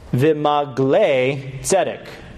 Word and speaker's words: v'maglei tzedek: The way that you would pronounce this in Hebrew is really important for the play on words that you get to see in this text. He says, v'maglei tzedek.